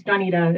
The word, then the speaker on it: Danetta: I like how you're saying my name, Rhonda. – Am I saying it right? – You know, it's creative. I like it. It's Danetta. It's really close, though.